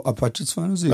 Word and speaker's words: Apache 207: Apache 207? Ich glaube so, Apache 207.